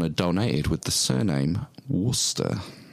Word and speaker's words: Worcester: She spells it like the sauce, W-O-R-C-E-S-T-R, which everyone I pronounce as Worcester, and it's just Worcester. – Worcester.